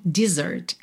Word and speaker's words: dessert: mas não muda a sua pronúncia. De Z para S, você não fala dessert, é mesmo dessert, é exatamente a mesma pronúncia, tá bem?